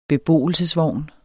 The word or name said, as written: BEBOELSESVOGNE